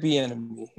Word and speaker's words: Bieniemy: Let me make sure I got his name right. Eric, was it Benemy? I say it last time. Eric Bieniemy. Eric Bieniemy.